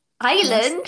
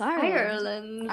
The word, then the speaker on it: Ireland: Because people say I pronounce it weirdly, and they say it's Ireland. – Island? – Yeah. – Where's the R? – It's not. It's Ireland. – Ireland. – You have to say the R.